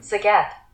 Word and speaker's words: Zagat: Zagat.